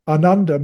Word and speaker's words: Ananda: It's a long A. So Ananda means deep contentment.